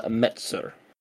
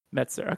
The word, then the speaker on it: Metzger: I always blank on how to pronounce his last name. – So it's Misha Chatterone and then yeah. Manuel Metzer. – Metzer, okay.